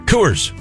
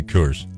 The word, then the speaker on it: Coors: Coors. What's wrong with that? I still don't understand what the problem is you and Jug Country have with the way I say Coors. – Coors. – It's Coors.